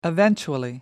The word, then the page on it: eventually: American English